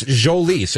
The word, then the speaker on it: Jolys Chacín: People thought it was Jolys. It's Jolys. It's a soft J. Jolys Chacín. – A soft J? – Soft J. – Not a silent J. – No, soft J. – So you just sort of Jolys. Yeah. – Okay. So Julius would be a hard J.